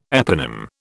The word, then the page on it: eponym: British English